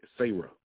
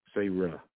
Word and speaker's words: Sarah: Sarah. It ain't even Sarah. It's Sarah. They got the emphasis. Stretch that A.